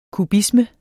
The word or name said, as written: kubisme